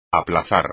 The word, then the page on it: aplazar: espagnol